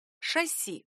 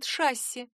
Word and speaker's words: шасси: Но допустимый вариант — шасси. Весь вопрос в том, для кого допустим. Как отмечено в Большом орфоэпическом словаре русского языка 2012 года, в профессиональной речи допускается ударение «шасси». Так говорят, например, пилоты самолетов. Но если вы не пилот, используйте вариант «шасси».